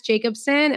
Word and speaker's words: Jacobsen: My name is at Jess Jacobsen and my last name is spelled J-A-C-O-B-S-E-N, not E-N, O-N.